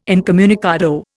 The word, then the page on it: incommunicado: Indian